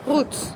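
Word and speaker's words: Roetz: Wie spricht man es aus? – Rutz. – Rutz. Rutz, ja. Ah, wie Rutz.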